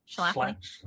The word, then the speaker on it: Schlafly: But you also, in Jesus and John Wayne, like Phyllis Schlafly, am I saying her name right? – Schlafly.